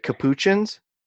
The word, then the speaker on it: capuchins: And then they're called, like, capuchins. Is that how you pronounce it? – Mm-hmm.